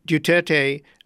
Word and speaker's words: Duterte: This new president, Rodrigo Duterte, how do you pronounce his name? Sorry. – Duterte is being described as a tough-talking, foul-mouthed imitation, if not even, perhaps not even imitation of Donald Trump.